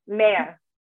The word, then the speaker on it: Mayer: The R is super soft. Mayor.